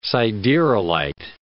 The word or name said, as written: siderolite